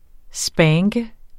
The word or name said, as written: spanke